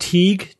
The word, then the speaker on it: Tighe: This one comes from, I believe the name is pronounced Teague, T-I-G-H-E.